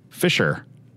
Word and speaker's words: fissure: The proper pronunciation, according to Google, is Fisher.